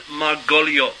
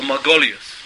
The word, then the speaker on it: Margoliot: His name was Reuven Margoliot, or Margolius, right, in the Ashkenazic pronunciation.